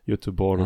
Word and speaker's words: Göteborg: Es ist auch mit, mit der äh, westschwedischen Stadt ähm, Göteborg, die spricht man auch, äh, also das G spricht man eben anders aus. Ich weiß nicht, man spricht dann auch irgendwie so Jötte, Jötteborg ah, oder so aus, irgendwie oh, sowas, keine Ahnung.